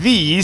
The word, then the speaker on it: Thee: It has two E's. So I say Megan Thee Stallion. – It's Megan Thee Stallion. – No, but that would just be one E. – But you're pronouncing it wrong. I'm pronouncing it correct. – The E is silent. – But also, it's already a word. Shakespeare made up this word. Thee, thou, you know, this is already a word. It's pronounced thee. If she wants to spell it T-H-E-E, it has pronunciation.